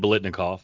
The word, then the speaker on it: Biletnikoff: It's the Fred Biletnikoff.